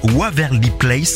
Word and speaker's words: Waverly Place: Puis autre sortie cette semaine, le reboot d'une série très connue dans les années 2000, c'est Waverly Place.